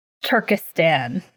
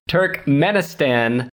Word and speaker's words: Turkmenistan: Turkmenistan.